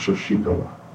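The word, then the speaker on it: Sasikala: Her name is pronounced Shoshikala. – Sasakala.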